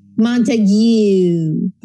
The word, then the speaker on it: Montague: Montague. – Like the Romeo and Juliet? – Exactly.